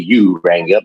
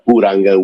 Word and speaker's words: Uranga: Well, you pronounce it the anglicized way, Uranga, but it's Uranga with the Spanish pronunciation, but that's okay.